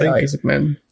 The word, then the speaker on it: Isaacman: Isaac Dan, is that you pronounce his name? – Yeah, Isaacman. – Isaacman, that's right.